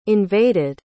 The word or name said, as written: invaded